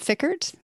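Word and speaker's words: Fikkert: I don't know if I'm pronouncing that name properly. – Yep, Corbett and Fickert.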